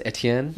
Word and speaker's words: Etienne: Travis Etienne.